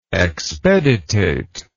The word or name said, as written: expeditation